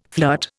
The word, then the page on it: flirt: Indian